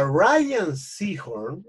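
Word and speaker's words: Rhea Seehorn: Una revisión completa, una entrevista a la Ria ah, perdón, a Ryan Seahorn, así se pronuncia, de hecho está como puesto, eh, loco, el personaje de ella no era nadie.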